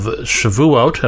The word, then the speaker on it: Shavuot: But next Thursday, May 28th, is the beginning of the Jewish holiday of Shavuot, and I hope I said that correctly.